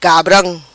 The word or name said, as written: Cabram